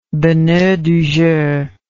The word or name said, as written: bonheur du jour